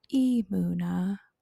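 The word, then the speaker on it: Emuna (אמונה): So I have to, it's Emuna. Emuna.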